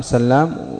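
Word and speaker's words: سلّام: أحدهما بماذا؟ بشد اللام سلام، والآخر بتخفيفها، والآخر بتخفيفها.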